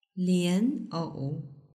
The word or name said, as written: lianou